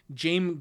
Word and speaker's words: Jame: Okay, well, my note is that it's interesting that Harris wrote Jame Gum insists on being called Jame with a silent S, despite his name being spelled James.